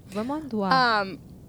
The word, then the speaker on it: Vermandois: Vermandois? I don't know. – Vermandois.